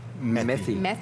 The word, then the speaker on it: Methy: Ben, dites-moi comment prononcer ça. M-E-T-H-Y. Methy.